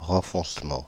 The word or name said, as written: renfoncement